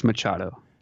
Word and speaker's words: Machado: Is it Machado or Machado? See, I always thought it was Machado. – It's Machado, definitely.